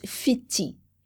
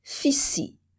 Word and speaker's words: Fidji: C'est le groupe des Fidji. Mais à Samoa, on prononce Fiti et à Tonga, Fisi. L'S est toujours dur.